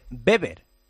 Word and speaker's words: Weber: Que te iba a decir que sí, que se escribe Weber, pero que se dice Beber, ¿vale? Beber. O sea que cuando lo pidas para tu casa, para tu vivienda, para tu oficina, acuérdate, Beber, con Beber.